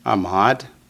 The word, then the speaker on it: Ahmad: Ahmad. – Ahmad. Two syllables.